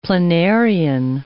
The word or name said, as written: planarian